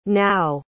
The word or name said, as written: nao